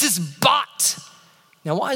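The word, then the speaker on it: bat (בת): It's pronounced bat, but it's spelled bath. If we were to pronounce it in English, it would be spelled bath, but in Hebrew, it would be pronounced bat.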